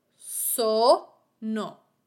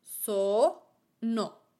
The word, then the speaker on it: sono: The word sono. Sono means I am. The accent goes on the first vowel. So-no. So-no. On the first syllable, so.